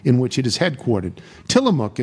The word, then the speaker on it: Tillamook: The mook part is pronounced like cook. Tillamook.